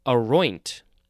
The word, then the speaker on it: aroint: Now we have aroint. So the second syllable is emphasized, and it has an N-T at the end, aroint.